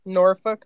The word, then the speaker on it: Norfolk: Oh, Worship just said that the tape guy is from Norfolk. I'm probably pronouncing that wrong. In UK. – Norfolk, that's right.